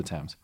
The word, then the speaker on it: Thames: I had to look up how to pronounce it. I feel so bad, but it's the Thames.